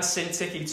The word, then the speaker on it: Syntyche: I entreat Euodia, and I entreat Syntyche. And let's just kind of agree in this room, that's what they're called and that's how they're pronounced, okay? Euodia and Syntyche, to agree in the Lord.